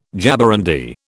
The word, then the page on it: jaborandi: British English